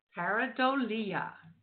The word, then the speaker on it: pareidolia: Pareidolia.